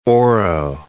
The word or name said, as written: Oro Valley